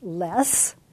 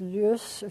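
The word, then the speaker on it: loess: The first word is by most of us pronounced less. Although my people who are authorities on this tell me it really is lus. If you want to say it properly, just give it a little lus sound to it.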